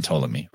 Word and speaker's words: Ptolemy: You know, Ptolemy, they don't even pronounce the P in Ptolemy, right?